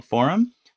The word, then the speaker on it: Forem: Dev is still alive and well, but we announced recently that the underlying software that powers Dev is called Forem. That's spelled F O R E M, and it stands for the phrase for empowering community.